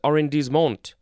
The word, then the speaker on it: arrondissement: And there's more at the word right, R-I-G-H-T. Ooh, we have a French word. It is arrondissement. I actually know how to pronounce that one. It is spelled A-R-R-O-N-D-I-S-S-E-M-E-N-T.